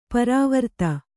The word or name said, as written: ಪರಾವರ್ತ